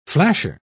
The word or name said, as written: flasher